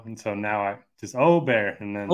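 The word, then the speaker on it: Åberg: Yeah, and kudos to you for getting the name right, because I still can't get used to saying Aubert after the Ryder Cup, when, you know, obviously he got a lot of airtime, and Aberg is the English pronunciation to me. So yeah. Aubert is technically correct.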